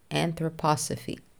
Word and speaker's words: Anthroposophy: Anthroposophy.